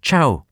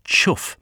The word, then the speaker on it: chough: Quirk of Raby, pronounced the name as chow. A contributor to British Birds says a Cornishman he knew always pronounced the word to rhyme with plough.